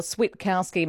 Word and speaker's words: Switkowski: But maybe, given finals are coming up and maybe given it will be Sam's final hurrah, we can finally start calling him Switter or Switkowski, making sure there's that emphasis on the T.